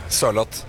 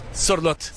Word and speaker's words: Sørloth: Sørloth. – Sørloth.